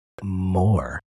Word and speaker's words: Moir: It's George Moore, which is spelled M-O-I-R, but it's George Moore.